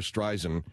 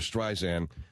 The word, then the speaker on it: Streisand: We had to go back in and recut everything because I said Barbara Streisand, and it's Barbara Streisand.